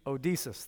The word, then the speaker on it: Odysseus: Homer um, tells this story about a man named I'm saying it wrong. Odysseus. Help me out. Odysseus, thank you. I knew there would be some Greek scholars here. Odysseus. Odysseus. Odysseus. Mobetta. Okay. Odysseus. Kind of like Zeus, Odysseus.